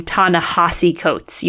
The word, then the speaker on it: Ta-Nehisi Coates: And the author of Between the World and Me pronounces his name Ta-Nehisi Coates. You would not know that from looking at it because the spelling looks like it should be Ta-Nehisi Coates. It's like with an I there, but Ta-Nehisi Coates is how he pronounces his name.